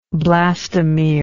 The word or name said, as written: blastomeric